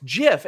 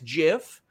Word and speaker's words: GIF: Uh, listen, somebody's saying it's a hard G, Sean, for, for GIF. No, it's GIF, and you want to know why it's GIF? Is because the guy who literally invented it says it's GIF.